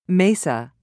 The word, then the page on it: mesa: British English